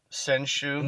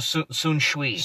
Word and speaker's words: Sun Tzu: and it's actually pronounced... – Senshu? – No, Sun Shui.